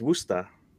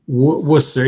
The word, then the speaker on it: Worcester: His bio on Instagram says that his first job was sorting cards at Rotman Collectibles in Worcester. Took a few years off from cards. That's right? It's Worcester. – Worcester, yeah. So Worcester. It's a colloquial pronunciation.